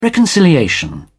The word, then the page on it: reconciliation: British English